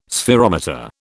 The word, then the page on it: spherometer: British English